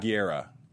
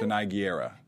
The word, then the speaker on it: Gurira: Yeah, I mean, how do you say her last G- Gurira? – Uh, Gurira, yeah. – Say it again. – Danai Gurira.